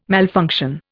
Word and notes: malfunction: Indian

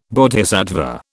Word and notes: Bodhisattva: British English

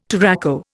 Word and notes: touraco: Indian

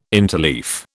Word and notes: interleaf: British English

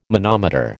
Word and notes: manometer: American English

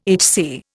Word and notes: HC: Indian

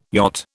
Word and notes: yacht: British English

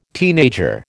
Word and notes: teenager: American English

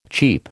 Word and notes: cheap: American English

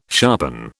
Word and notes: sharpen: British English

